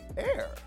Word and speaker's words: Aire: Kylie posted pics of her baby boy, formerly known as Wolf, on Insta, revealing his new name, Air. Spelled A-I-R-E. She confirmed the pronunciation in the comment section of a fan account.